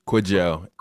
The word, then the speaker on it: Kwajo: Is that how I pronounce Kwajo. – It? – Kwajo.